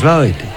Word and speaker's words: Lloyd: Lloyd, que no, que es Lloyd. Entonces, hablando con la amiguita de Carlos Solázar, nos ha dicho que se dice así. – Charles Lloyd. – Eso, Charles Lloyd.